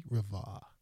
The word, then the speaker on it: Rivard: Should I pronounce the D? Rivard.